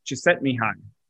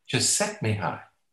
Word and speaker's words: Csikszentmihalyi: Csikszentmihalyi. – Csikszentmihalyi.